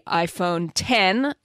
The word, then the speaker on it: iPhone X: And then the iPhone 10, it's the letter X, but they pronounce it 10. – Roman numeral 10.